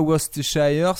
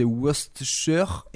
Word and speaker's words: Worcestershire: Je prends les news et j'en tire le blues et je mélange le tout avec la sauce Worcestershire. oui, la Worcestershire la Et après, j'ai fait merde, comment elle se prononce celle-là déjà J'ai fait c'est pas Worcestershire, c'est Worcestershire.